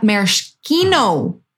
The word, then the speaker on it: maraschino: How'd you say it? Say it again. – Maraschino, which Uncle Brad told me how to say it, right? About. – I love it. – You know what? – All right. – I say it wrong once, you correct me, I'll never say it wrong again.